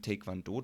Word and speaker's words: Taekwondo: Und die Taekwondo-Technik ist stark eben auf Schnelligkeit und Dynamik ausgelegt und im Taekwondo dominieren Fußtechniken deutlicher als in vergleichbaren Kampfsportarten.